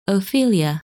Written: Ophelia

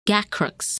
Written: Gacrux

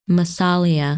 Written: Massalia

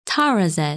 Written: Tarazed

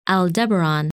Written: Aldebaran

